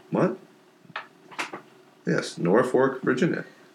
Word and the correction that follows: Norfolk: Norfolk. – N-O-R-F-O-L-K. – I'm sorry, that's not pronounced North Fork.